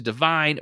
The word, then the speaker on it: Dyvyne: Rocket then goes and speaks to Divine, which is spelled D-Y-V-Y-N-E, if you're wondering.